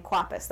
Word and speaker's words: Kwapis: I definitely, because I've been listening to Office Ladies, that podcast, I've been hearing about Ken Kwapis. That is how you pronounce it.